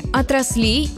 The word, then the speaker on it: отраслей: Еще некоторое время назад правильным считался единственный вариант – ударение на первом слоге – отрасли, отраслей, отраслям. Вариант с ударением на последний слог – отраслей – помечали как разговорный.